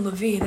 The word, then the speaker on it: Levine: No, Baylen Levine. That's how you say his name.